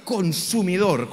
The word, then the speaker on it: consumidor: Muy bonito lo de la gracia, pero Dios es fuego consumidor, con acento en la R. Consumidor.